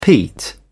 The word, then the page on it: peat: British English